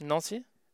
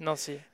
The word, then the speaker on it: Nancy: Nancy. – Okay, yeah. Nancy is how you say it? – Nancy.